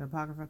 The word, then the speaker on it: Apocrypha: and if you know the Apocrypha, is that how you say it? The Apocrypha? – Mm-hmm.